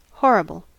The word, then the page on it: horrible: American English